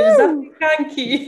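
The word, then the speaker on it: zapiekanki: Sapiekanki? – No, you're really good in Polish. Sapiekanki, yeah.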